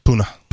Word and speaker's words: Puna: Puna.